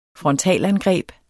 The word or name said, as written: frontalangreb